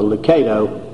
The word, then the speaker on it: Lucado: said that his name is pronounced like similar to tomato, long A, so Lucado, and so we'll try to do that.